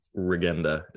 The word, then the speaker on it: Regenda: It was Regenda, not Regenda. – I think it's Regenda. – Regenda is the proper Slovak pronunciation.